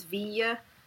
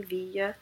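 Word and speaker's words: Viy: It's V-I-Y. I think it's Via. Is the way they pronounce it in the movie, Via.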